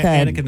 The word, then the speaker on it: Annika: Is it Anika or Annika? – Annika Niles. – She's amazing. – Oh, yeah. – I'm sure that Neil Peart's up there in rock and roll heaven saying, go, Annika.